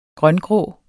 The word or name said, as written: grøngrå